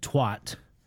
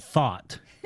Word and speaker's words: thot: I thought you had said twat. You actually said thought. – Yes, thought. – Oh, which is spelled T-H-O-T.